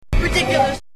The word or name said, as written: ridiculus